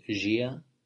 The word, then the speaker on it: dia: apparently, they don't have a D. They pronounce it J. Is that right? – For what? D? – Yeah. So dia would be gia in Portuguese.